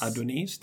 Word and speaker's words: Adonis: Eh, vi har diskuterat boken Våld och Islam av Adonis. – Adonis, det är där betoningen ligger.